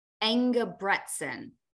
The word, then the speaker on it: Engebretson: Engebretson.